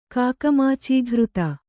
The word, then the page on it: ಕಾಕಮಾಚೀಘೃತ: Kannada